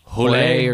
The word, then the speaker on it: Hole: And Fassbender plays a character named Detective Harry Hole. Apparently in Norwegian, it's pronounced like Hule or something.